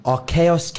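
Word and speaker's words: Archegos: Archaos Capital Management.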